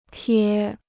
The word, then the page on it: 撇: piě